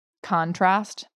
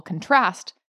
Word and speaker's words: contrast: So take a word like contrast, the noun, the emphasis is on the first syllable. But if you put the emphasis on the second syllable, contrast, you've now got a verb, and that's the action version, even though it's the exact same word.